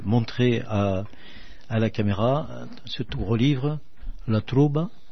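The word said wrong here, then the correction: La Tròba: c'est comme ça qu'on dit ? – La Trobo. – La Trobo, voilà. Oui, le A se prononce haut.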